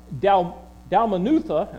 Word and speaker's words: Dalmanutha: Well, Dalmanutha, and I will praise Bob.